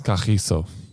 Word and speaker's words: Kagiso: And how do you pronounce his first name? Because you're the only person who says it like this. – Kagiso. That's how you say it, yep.